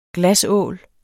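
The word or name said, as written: GLASÅL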